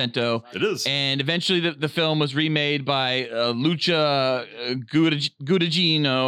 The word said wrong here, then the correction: Guadagnino: Luca Guadagnino. Luca Guadagnino.